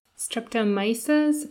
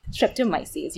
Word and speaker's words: Streptomyces: So you focus on the bacterial genus Streptomyces? Did I say that right? – Streptomyces. You were so close. – Myces. At the end.